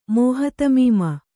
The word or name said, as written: ಮೋಹತಮೀಮ